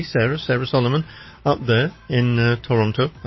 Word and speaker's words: Toronto: But somebody from Canada would pronounce it Toronto. Toronto.